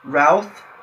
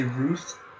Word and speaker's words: Routh: Routh, like South. I still hear people say Ruth, which I don't know where you get that from.